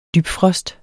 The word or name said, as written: dybfrost